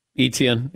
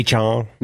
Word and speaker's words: Etienne: Also, Travis Etienne is running his 40 today, although I might be pronouncing it wrong. – Etienne, it's okay. – Etienne.